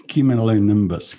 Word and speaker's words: cumulonimbus: Cumulonimbus clouds.